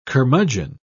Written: curmudgeon

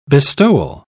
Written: bestowal

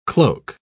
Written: cloak

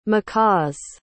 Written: macaws

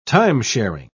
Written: time-sharing

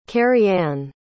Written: carrie ann

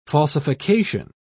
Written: falsification

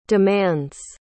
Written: demands

